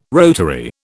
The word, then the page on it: rotary: British English